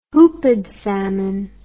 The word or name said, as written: hoopid salmon